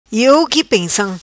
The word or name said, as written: Pensam Iou Qui